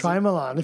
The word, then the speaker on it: Shyamalan: Shyamalan. If you're going to fucking have one of his films, say it right.